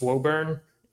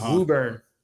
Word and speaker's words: Woburn: He got a house in W-O-B-U-R-N. So I thought it was Woburn. It's Woburn.